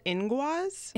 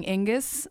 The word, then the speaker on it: Inguz: It's called Inguaz. – Inguaz. – So it's spelled Inguz. – Okay. – Yeah. – I was totally saying Ingus.